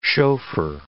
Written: chauffeur

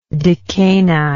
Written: decani

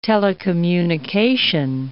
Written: telecommunication

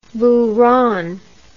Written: buran

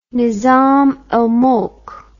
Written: nizam al mulk

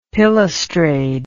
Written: pilastrade

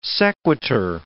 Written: sequitur

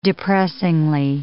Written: depressingly

